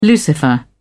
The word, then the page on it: lucifer: British English